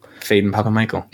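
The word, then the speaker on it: Phedon Papamichael: And that's Fedon Papamichael. Certainly mispronounce some of those. – Yeah, I think it's just Fedon Papamichael. – Fedon Papamichael.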